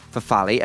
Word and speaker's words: farfalle: Producer Chris has also written me a pronunciation guide for farfalle. Apparently, it's pronounced farfalle. And Chris has written, emphasis on the first syllable, same pattern as totally. Farfalle, totally. Look, I don't.